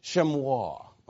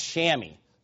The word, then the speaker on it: chamois: Or as the British would say, chamois. Okay. Does it really matter? No. But American pronunciation, because we're an American church, it's chamois. That does not look like that at all. So I don't... Anybody that doesn't know how to say that, I get it. But it's a weird, weird pronunciation.